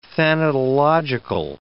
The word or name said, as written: thanatological